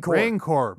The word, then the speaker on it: Rancor: Rancor. The Rancor pit.